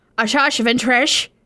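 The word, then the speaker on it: Asajj Ventress: Ashash Ventresh? – That's the best pronunciation of that ever.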